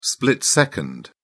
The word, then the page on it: split-second: British English